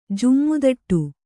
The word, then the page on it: ಜುಮ್ಮುದಟ್ಟು: kannada